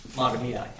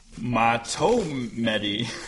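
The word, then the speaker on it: Mahtomedi: Matamidai. – Not what I would have guessed. – Will you tell us? – Matomedi. Matomedi. – Matomedi? – Here's the question.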